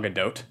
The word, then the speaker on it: Gadot: And then, of course, the beautiful Gal Gadot driving away. – You know what I've heard is actually that it's pronounced Gal Gadot.